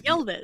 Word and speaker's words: Yelvis: How did he say his name? Yelvis? Yelvis? – Yelvis. – Yeah. Elvis with a Y in the beginning.